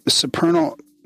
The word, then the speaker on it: supernal: So teaches uh, the supernal. No, oh, see, I already got it. Now I can't. How do you pronounce that? Supernal. Right. Okay, I was right.